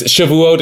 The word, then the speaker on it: Shavuot: Well, like, so it's Shavuot is one pronunciation. Shavuos is like the more European pronunciation.